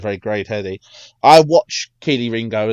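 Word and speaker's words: Kelee Ringo: It's the cornerback, Kelly Ringo. – Keely Ringo, you're right the first time. – Keely should be double E, surely. – It's Keely Ringo. – Well, that's wrong. It's spelt Kelly.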